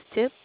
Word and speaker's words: Tip: My name is Tip, T-I-P.